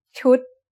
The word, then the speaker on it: ชุด: What's the tone of ชุดIt's a low-class consonant with a dead syllable and a short vowel that makes it high tone.